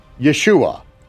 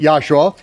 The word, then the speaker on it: Yeshua: So if you pronounce it Yahshua, as I do and as I did, and occasionally do, because I want it to be understood that that's the form of the name.